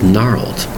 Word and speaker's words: gnarled: It's one syllable word, but notice the spelling. G-N-A-R-L-E-D. That's right. The G is totally silent, but we spell it with the letter G. Again, the word is gnarled. G-N-A-R-L-E-D.